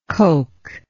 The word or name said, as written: coky